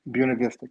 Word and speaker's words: Buena Vista: Is that in Buena Vista? – Buena Vista, correct. – Yes. Buena Vista, that's how you say it.